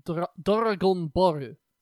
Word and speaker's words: Dragon Ball: Specifically pronounced as Dragon Boru.